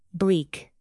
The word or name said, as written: bleak